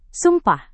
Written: sumpah

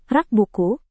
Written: rak buku

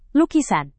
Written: lukisan

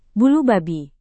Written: bulu babi

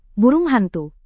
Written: burung hantu

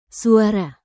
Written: suara

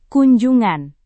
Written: kunjungan

